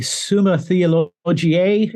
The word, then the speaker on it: Summa Theologiae: And so when you think of Thomas Aquinas, in the Summa Theologica, or the Summa Theologiae, as it's pronounced, or the Summa Contra Gentilis, you see kind of a Greek Christian synthesis.